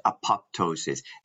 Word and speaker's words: apoptosis: Apop- people say apoptosis.